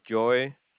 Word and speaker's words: Joy: name is Joy.